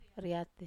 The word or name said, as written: riat